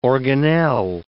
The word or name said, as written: organelle